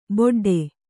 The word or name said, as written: ಬೊಡ್ಡೆ